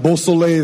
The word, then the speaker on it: Beausoleil: And Beausoleil is a French word. My parents are from St. Lucia. It's Creole. It means beautiful sun. I love to pronounce my name in that way, specifically because I believe names have power.